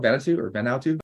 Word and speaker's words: Vanuatu: Vanuatu.